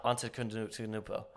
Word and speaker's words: Antetokounmpo: This man, for those of you who don't know, Giannis Antetokounmpo, Gusenauts, hopefully, you know, I pronounced that right, just like I did, Gusenauts. – Antetokounmpo. But, yeah, Antetokounmpo. – Antetokounmpo. – Okay, we got it.